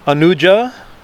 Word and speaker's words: Anuja: She was Adrishati Anuja Vishnuhu. Anuja, the long syllable.